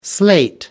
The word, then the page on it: slate: American English